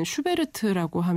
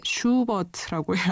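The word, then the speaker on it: Schubert: 제가 한국에서는 슈베르트라고 발음을 하는데 독일에서는 슈베르트라고 하면 못 알아들어요. 예, 슈버트라고 해야 합니다.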